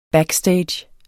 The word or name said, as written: backstage